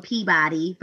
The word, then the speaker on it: Peabody: It's spelled Peabody, but they say Peabody. I know. Peabody, Mass.